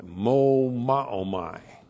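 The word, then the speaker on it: Mo Ma O Mai (μωμάομαι): M long O M A O M A I. Mo Ma O Mai.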